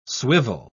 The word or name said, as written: swivel